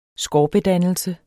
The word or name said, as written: SKORPEDANNELSE